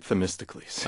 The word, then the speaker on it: Themistocles: There are a number of interesting characters whom you portray quite vividly in this book, starting with, and you'll have to forgive my pronunciation and hopefully correct me, Themistocles. Is that close? – Let's make it Themistocles.